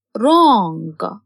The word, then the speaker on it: wrong: W is a silent letter in wrong.